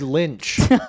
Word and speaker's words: Lynch: Rhymes with inch. – Okay. – David Lynch.